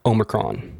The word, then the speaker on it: Omicron: I believe it's pronounced Omicron.